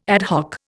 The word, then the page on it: ad hoc: Indian